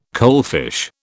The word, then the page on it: coalfish: British English